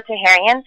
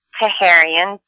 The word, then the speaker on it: Taherian: So it's Ursula Taherian, U-R-S-U-L-A, Taherian, T-A-H-E-R-I-A-N.